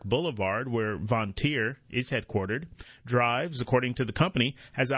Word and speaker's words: Driivz: Drivz, spelled D-R-I-I-V-Z, develops smart, or maybe Drivz is how they pronounce it, develops smart EV charging and energy management software.